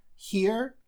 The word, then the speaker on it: here: Here, here – это «здесь». Не путаем со словом hear, которое означает «слышать что-то». Оно пишется по-другому, через E-A-R, а это через E-R-E. Произношение абсолютно идентичное.